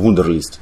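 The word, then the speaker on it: Wunderlist: Вандерлист. Так, да, да, да. – Просто он пишется не через О, как... – Я а помню, а через, через У. – да. То есть, получается, вундерлист.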